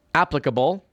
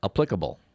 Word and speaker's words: applicable: Turns out in the word applicable, that's the correct pronunciation. It's not applicable. But the word T-R-A-N-S-I-E-N-T, it's got two syllables, not three.